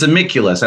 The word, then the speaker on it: Amiculus: but it's spelled in the Roman way, AMICVLVS. Those V's are U's, but people are like, how do you pronounce that? And even when I tell them those are U's, it's like, it's amiculus. I like to compare it to, like, you know, Benicula or something like that.